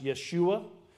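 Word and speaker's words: Yeshua (ישוע): It would have been pronounced Yeshua in the Hebrew, which is Joshua.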